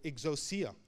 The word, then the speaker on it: exousia: But the power of authority that he gave them was what they call exousia. Can we say that? Exousia? It's spelled E-X-O-U-S-I-A.